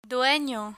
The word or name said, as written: dueno